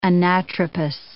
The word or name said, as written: anatropous